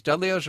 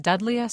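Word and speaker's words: Dudleya: Is it pronounced Dudlea? Sorry, I thought it was Dudlea. – Yeah, I think it can go either way.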